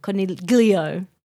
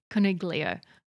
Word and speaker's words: coniglio: My dad has made that joke whilst watching the footy because they also do pronounce it wrong. Coniglio. – Coniglio.